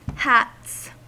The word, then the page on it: hats: American English